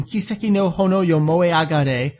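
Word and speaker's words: Kiseki no Honō yo Moeagare: It's got the short version of the vocal opening theme, Kiseki no Hono Yomoe Agare. I said it properly after all these months. Seems like a year now. Finally got the extra ah in there.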